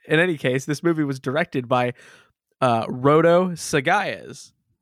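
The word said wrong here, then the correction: Sayagues: I'm sorry, I said his name wrong. Sagaez. Put the syllable in the wrong spot.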